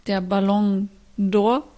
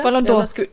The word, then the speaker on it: Ballon d'Or: Es war jetzt der Ballon d'Or. Josie, hab ich es richtig ausgesprochen? – Super. Mhm. Genau, ein bisschen schneller, ein bisschen flüssiger. – Ballon d'Or.